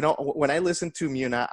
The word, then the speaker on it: Muna: Let me ask you about Muna, right? Is that how you say it? – Muna.